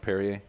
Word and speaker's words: Perrier: Today we're drinking Peru. – Peru. – Sparkling water. – Oh. – I don't get that. – Pronounced Perrier?